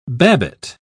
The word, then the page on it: babbitt: British English